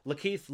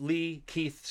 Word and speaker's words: Lakeith: Well, it's Lakeith Lee Keith Stanfield.